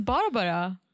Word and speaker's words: Barbara: It's Barbara. – Yeah, just the way you're saying it, you're putting the accent on the wrong syllable.